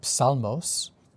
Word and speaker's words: psalm: So that might also remind you of the Greek word psalmos or psaltery, which comes from Greek. – So, psalmos as in Tehillim? – Right, exactly. So the word psalm. – Right, with the silent P.